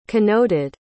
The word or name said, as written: connoted